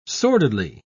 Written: sordidly

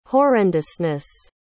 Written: horrendousness

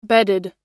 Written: bedded

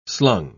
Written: slung

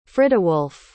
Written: friduwulf

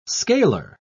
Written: scalar

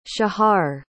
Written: shahar

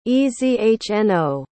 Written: ezhno

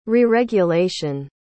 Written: reregulation

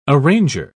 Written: arranger

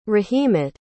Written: rahimat

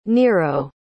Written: Nero

